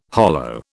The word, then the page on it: hollo: British English